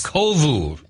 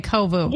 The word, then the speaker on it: Kovu: I don't even know how to pronounce the name of this. – Kovu. – Okay, Kovu. – Yeah, I'm rolling with Kovu.